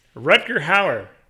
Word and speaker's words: Rutger Hauer: You called him Hudger Rao last week when we were watching the movie. – Hudger Rao. – Hudger Rao. – Reiterated the pronunciation. God damn it. Rutger Hauer.